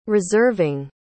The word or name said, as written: reserving